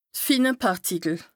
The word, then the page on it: d' fine Pàrtikel: Bas Rhin